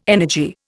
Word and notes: energy: Indian

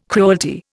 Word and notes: cruelty: Indian

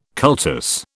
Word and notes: cultus: British English

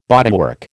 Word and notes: bodywork: American English

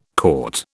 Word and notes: caught: British English

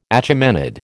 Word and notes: Achaemenid: American English

